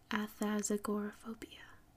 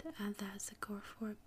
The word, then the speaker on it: athazagoraphobia: So, the main word is agoraphobia, and the beginning is athas. So, athasagoraphobia. So, it's not too hard. Athasagoraphobia, I think.